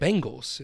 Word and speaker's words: Bengals: How do you say it? – Bengals. It's with an E.